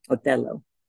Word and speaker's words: Otello: And that's how you say it in Italian, Otello.